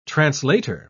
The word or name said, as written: translator